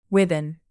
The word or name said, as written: ribbon